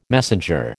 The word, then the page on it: messenger: American English